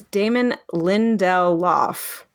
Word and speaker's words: Damon Lindelof: I'm adding extra syllables. Oh, my God. – You're just adding extra, like, words, consonants. – It's Damon Lindelof.